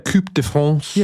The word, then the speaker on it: Coupe de France: Coupe de France.